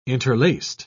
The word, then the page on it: interlaced: British English